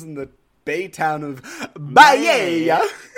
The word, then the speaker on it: Baiae: So our wild tale begins in the bay town of Baiae, and I'm going to pronounce it that way for the rest of the time, but for anyone who wants to correct me, it's spelled B-A-I-A-E. – Baiae. – Baiae. Baiae. – That sounds right.